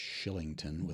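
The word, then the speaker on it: Kylington: Oliver Killing, I guess it's pronounced Shillington with a K.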